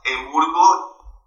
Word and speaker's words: EMURGO: Y todo este tipo de noticias nosotros los ponemos en Twitter, en nuestro handle ah, Emburgo a guión abajo io O. Eh, se escribe E-M-U-R-G-O a este guión a, abajo y latina O.